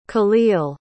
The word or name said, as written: kahlil